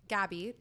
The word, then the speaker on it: Gaby: My username is @Gaby, G-A-B-Y.